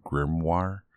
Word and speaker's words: grimoire: See, I have so much trouble remembering that word, grimoire. I don't know why. It's just maybe because it's a newer word to me that, you know. But yeah, you're saying it right.